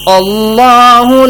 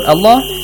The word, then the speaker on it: Allah: When you say Allah, there's no like la. Like fatha? – No. Because because we only have lam. You pronounce the lam like le. But there's no lam. Like you don't pronounce the lam that strong in it. – There are two lam. Look. It has a tashdid, a shadda.